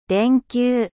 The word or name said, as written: ampoule